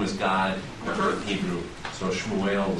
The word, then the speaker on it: Shmuel: The name Samuel in Hebrew, pronounced Shmuel, means name of God. – Oh, interesting. – So, pronounced how? – Shmuel.